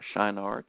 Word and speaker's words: Shinar: And this land of Shinar, or Shinar, depending on your pronunciation, interesting place, is where mainly Babylon is located.